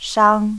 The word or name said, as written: shang